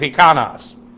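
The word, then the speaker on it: Hikanos (ἱκανός): hikanos, H-I-K-A-N-O-S. Hikanos, actually, the accents on the last syllable.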